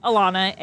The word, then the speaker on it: Alana: So, growing up and as a child, it's Alana, A-L-A-N-A.